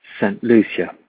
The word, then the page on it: St Lucia: English, GB